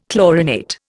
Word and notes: chlorinate: Indian

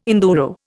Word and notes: enduro: Indian

